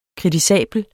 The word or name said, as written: kritisabel